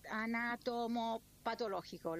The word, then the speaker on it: anatomopatológico: Luego, la cosa más importante que vamos a esperar es el examen anatomopatológico. ¿Lo dije bien? – Impecable lo dijiste.